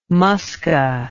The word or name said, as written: musca